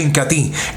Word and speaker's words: Incatea: Se escribe Incatea, pero se pronuncia Incatí,